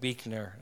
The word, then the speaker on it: Buechner: I love the writer Frederick Buechner. It's spelled B-U-E-C-H-N-E-R, but it's pronounced Buechner. I don't know why.